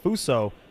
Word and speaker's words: Fusang: So what it looks like is Fuso. Sorry to cut you off there, Vic. It's spelled F-U-S-A-N-G, but it's pronounced Fuso.